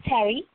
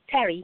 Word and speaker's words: Terry: It's Terry. Terry. – And you're saying Patty, right? – No, Terry with a T.